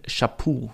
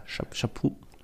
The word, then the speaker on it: Chaput: Das ist Michael Chaput, würde ich jetzt sagen. Ist Kanadier, darum wäre es ja Chaput. Chaput. Oder Chaput aber ich glaube, das T spricht man nicht aus.